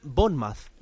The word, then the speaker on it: Bournemouth: Había alguno todavía que le decía a Cañizares por la última intervención, se nota que en casa no te dejan hablar mucho, Se pronuncia Bournemouth, la R es muda y la O larga.